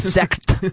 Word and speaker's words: sect: you have to just really pronounce the T. A sect.